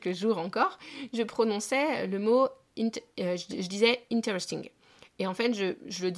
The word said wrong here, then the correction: interesting: En fait, c'est pas interesting, c'est interesting. Il faut vraiment insister sur cette première syllabe.